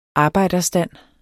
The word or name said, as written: arbejderstand